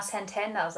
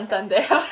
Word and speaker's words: Santander: Dezember 1957 in, ich will mal Santander sagen, aber das ist nicht, richtig, nicht die richtige Aussprache. – Santander.